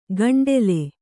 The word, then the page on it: ಗಂಡೆಲೆ: gaṇḍele